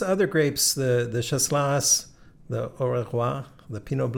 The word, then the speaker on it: Auxerrois: There is a Auxerrois. I'm not pronouncing that correctly. – Auxerrois.